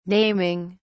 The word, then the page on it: naming: British English